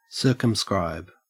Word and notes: circumscribe: Australian English

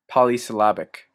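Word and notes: polysyllabic: Canadian English

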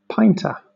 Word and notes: pinta: British English

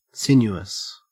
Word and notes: sinuous: Australian English

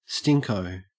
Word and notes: stinko: Australian English

